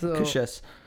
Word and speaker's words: Cassius: The way you actually supposed to pronounce it, Cassius.